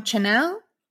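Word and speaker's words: Chanyeol: So I also have another record, which is, and again, forgive me if I pronounce it wrong, Chanel? Did I say that right? – Yeah.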